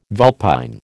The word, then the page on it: vulpine: American English